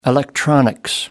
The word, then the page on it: electronics: American English